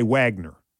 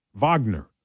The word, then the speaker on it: Wagner: If you look at it at print, we Americans would say Wagner. W-A-G-N-E-R, Wagner. It's I know in Germany, W's are pronounced as V's.